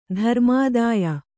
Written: ಧರ್ಮಾದಾಯ

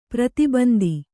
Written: ಪ್ರತಿಬಂಧ

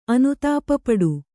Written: ಅನುತಾಪಪಡು

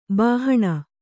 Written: ಬಾಹಣ

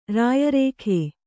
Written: ರಾಯರೇಖೆ

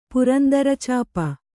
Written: ಪುರಂದರಚಾಪ

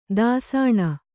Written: ದೂಸಣೆ

